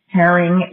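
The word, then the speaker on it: Hiering: So my name is Lisa, L-I-S-A. Herring is my last name, and I'll spell it. It's H-I-E-R-I-N-G.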